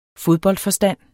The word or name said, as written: fodboldforstand